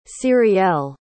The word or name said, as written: cyrielle